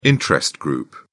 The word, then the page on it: interest group: British English